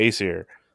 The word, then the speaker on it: Aesir: Yeah, the Aesir.